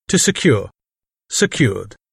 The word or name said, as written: secure